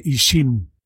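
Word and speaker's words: ixim: ¿Cómo se dice? – Se dice Ishim.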